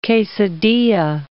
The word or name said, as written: quesadilla